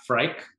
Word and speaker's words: Fryc: And she comes all the way from Seattle, Diana Fryc.